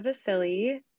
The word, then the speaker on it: Vasile: Yeah, so I'm Gabriella Vasile.